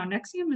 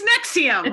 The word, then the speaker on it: NXIVM: No, Nexium is for heartburn. – Hold on. Nexium. It's Nexium.